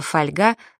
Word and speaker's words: фольга: Скажем, сегодня даже от людей, занимающихся русским языком профессионально, не услышишь слово «фольга» с ударением на первом слоге. Что еще за фольга такая? Только фольга. Загляните в словарь. Вы будете удивлены. Правильный первый вариант.